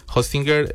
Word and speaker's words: Hostinger: Hostinger es como Hostinger.